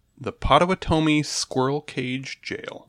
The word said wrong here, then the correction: Pottawatomie: Lately, so I will correct your pronunciation in as far as American marketing has suggested. It's Pottawatomie.